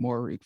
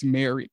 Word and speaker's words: Moehrig: I've been calling him Moehrig for the longest. It's Moehrig, according to the announcers.